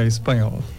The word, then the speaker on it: Spagnol: Isso mesmo, Marco Aurélio Spagnol, como que eu falo o seu sobrenome, assim mesmo? Bom dia. – Bom dia, Juliana, é espanhol.